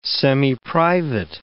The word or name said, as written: semiprivate